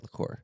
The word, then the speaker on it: liqueur: Look, how do you pronounce it? – Liqueur? – Yeah, liqueur.